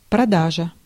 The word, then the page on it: продажа: /prɐˈdaʐə/